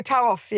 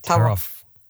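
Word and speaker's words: Taruf (تعارف): And I think it's pronounced Taruf. – Oh, Taruf, yeah. – Taruf.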